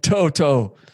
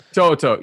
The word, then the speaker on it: To'oTo'o: To- God, I, wh- how do we say this kid's name? It's uh, To'oTo'o. To'oTo'o. Yeah.